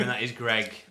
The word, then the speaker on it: Greig: Is it Greg or Greg? – I mean, it says it's spelled Greg. – Is it? – Greig, that's Greg. – It's Greg. – Greg. – In Scotland, that is Greg.